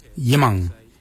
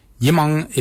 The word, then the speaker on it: yimoong: So for forehead, we say yimang. Yimang is spelled Y-I-M-O-O-N-G, yimang. Yimang is forehead. The next word is head. For head, we say kart. Kart. Kart is spelled G-A-A-D, kart.